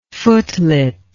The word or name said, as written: footlet